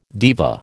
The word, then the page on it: diva: American English